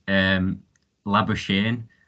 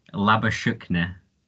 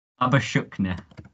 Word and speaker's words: Labuschagne: The English pronunciation is Labuschagne, or he's originally born in South Africa and he pronounces it Labuschagne.